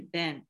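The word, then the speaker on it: ben (בן): 那他这边为什么要用石头来用这句经文呢因为石头和这个儿子在希伯来语里面它的发音是很相近的。那儿子的话就是 ben 石头就是 eben，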